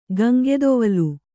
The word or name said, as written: ಗಂಗೆದೊವಲ್